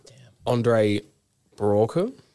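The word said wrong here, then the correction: Braugher: Oh, yeah. – I'm not sure entirely how to pronounce the last name. – Who's that? – Captain Holt. – Captain Holt. – Andre Brauer. – Thank you.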